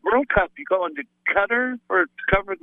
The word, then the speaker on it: Qatar: Oh, oh no, it's Qatar.